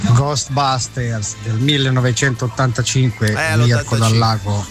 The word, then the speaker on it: Ghostbusters: Con la pronuncia inglese Ghostbusters. Brava Laura.